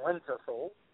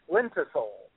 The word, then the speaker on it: lenticel: What is it called? What the holes called? – Lenticel. – Lenticle? – Lenticel. – Spell that. – L-E-N-T-I-C-E-L, I believe, is how you spell it. Lenticel.